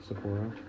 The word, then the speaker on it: Sapporo: Sapporo, yeah.